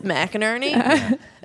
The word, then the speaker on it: McInerney: Like, it's not like, I'm not like being like, hey, you should know it's McInerney.